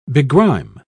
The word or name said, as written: begrime